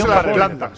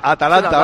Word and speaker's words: Atalanta: En realidad es Atalanta. – Atalanta. Ahora te lo he dicho bien, ¿no? Atalanta.